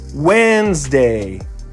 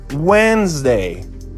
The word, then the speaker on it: Wednesday: Opa, está escrito Wednesday, mas esse D não é pronunciado jamais. Wednesday parece muito com o dia do quando. Wednesday. Wednesday.